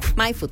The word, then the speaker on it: Maifud: Se escribe M-A-I-F-U-D, MyFood, tal cual, lo escuchas. – Para que, no es como vayan, en inglés, es como no en español. – Bien en español.